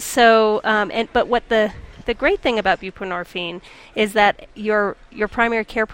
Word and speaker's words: buprenorphine: And one of them is a tax credit for providers of, how do you pronounce that? – Buprenorphine.